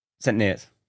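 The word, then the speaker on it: St Neots: St. Neots. – Neots. Yeah, I'm saying it right.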